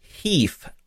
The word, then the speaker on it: HEIF: The pronunciation of the acronym is HEIF, like beef with an H. So in case you were wondering how to pronounce that, that's how it's pronounced.